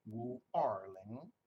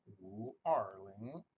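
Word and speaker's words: Wu Erling (五二零): In Chinese, 520 is pronounced Wu Erling, Wu Erling.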